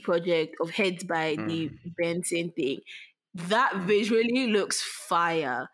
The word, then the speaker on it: BNXN: Pronounced as Benson, formerly known as Buju to your ears.